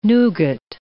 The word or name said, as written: nougat